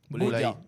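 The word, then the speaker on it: Boulaye Dia: Infatti volevamo anche chiederti della pronuncia di Didia, Bulaidia o Bulaiedia? – Della Salernitana. – Bulaidia. – Bulaidia. – Bulaidia. Sì. Sì, sì, sì. Io ho sempre detto Bulaidia, vi dico questa.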